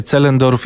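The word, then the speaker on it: Zehlendorf: A to dzielnica wymawia się Zellendorf, jak słusznie poprawił mnie nasz specjalista od języka niemieckiego Paweł Turski.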